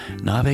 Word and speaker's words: Nave: Nave is spelled N-A-V-E.